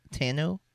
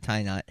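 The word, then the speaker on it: Tainot: Mercedes Tano, right? – Because you know, you don't pronounce the T, because she makes it very clear it's not tie knot.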